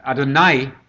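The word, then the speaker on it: Adonai (אֲדֹנָי): Adonai is the alternative divine title, 449 times in the Hebrew Bible. I think your computer will show you. That's Adonai. It's spelled A-D-O-N-A-I, sometimes A-Y. Adonai, pronounced.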